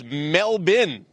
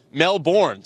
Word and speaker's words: Melbourne: Apparently, it's Melbourne. That's how the locals pronounce it, not Melbourne.